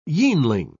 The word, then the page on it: yeanling: British English